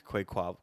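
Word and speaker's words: Quaquaval: Quaquavel, Quaquavel, Quaquavel.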